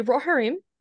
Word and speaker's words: Rohirrim: Say it again. – The Rohirrim. – Rohirrim? Is that how you say it? – Yes.